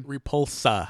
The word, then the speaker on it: Repulsa: So the Zero starts off about the Green Ranger, and you learn that Rita Repulsa, is that how you say her name? – Repulsa.